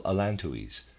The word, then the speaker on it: allantois: Readers aside, I'm pronouncing the word A-L-L-A-N-T-O-I-S as allantois. End of readers aside.